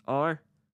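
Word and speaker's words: Are (あれ): Well, we have the words like this, that over there, and which in Japanese are? – Kore, pronounced ko-re. Sore, pronounced so-re. Are, pronounced a-re.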